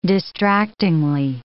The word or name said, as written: distractingly